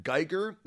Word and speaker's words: Guigar: And my handle is at Guigar. That's my last name. It's spelled like guitar, but with a G for a T.